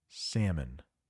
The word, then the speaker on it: salmon: We got the rare silent L in that word. It is pronounced salmon.